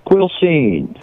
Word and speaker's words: Quilcene: Quilcene, Washington. Am I saying that right? – Quilcene.